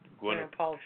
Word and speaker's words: Gwyneth Paltrow: Gwyneth Paltrow.